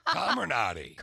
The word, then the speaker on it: Comirnaty: Comirnaty. Calm or naughty.